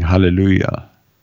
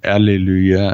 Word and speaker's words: Hallelujah: So you can't get more fundamental in your praise than saying Hallelujah. And that's not Alleluia, as some people spell it, or any of the other mispronunciations. It's Hallelujah. Hallelujah.